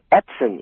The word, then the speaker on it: Edson: It's Edson, E-D-S-O-N.